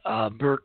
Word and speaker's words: Birx: It's video of a White House briefing where Dr. Anthony Fauci and Dr. Dorothy Birch, Birx, I'm going to pronounce that correctly, with an X, are leaving the stage.